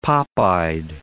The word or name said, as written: pop-eyed